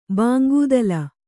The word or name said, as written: ಭಂಗತಾಳ